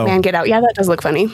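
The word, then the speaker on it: Mangetout: Wet Legs, Man Get Out, which I badly mispronounced in the video I made because it's all one word, man get out. I thought it was like manga too. I thought it was French. It's not.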